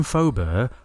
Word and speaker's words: Faubert: Faubert, F-A-U-B-E-R-T.